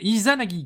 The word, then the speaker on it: Izanagi: Bah, c'est fait par Inazagi, Inazagi Games, pardon, désolé. J'arrive même pas à, prononcer leur nom, je suis désolé. Inazagi, Izanagi Games, Izanagi Games.